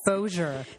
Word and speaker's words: Bossier: Bossier. B-O-S-S-I-E-R.